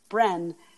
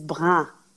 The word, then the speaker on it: brin: I would always pronounce it Bren, and my distiller would be like, it was spelled B-R-I-N.